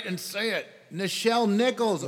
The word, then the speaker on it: Nichelle Nichols: Say it right. Nichelle Nichols.